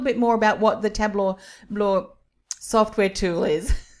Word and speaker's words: Tableau: Tableau.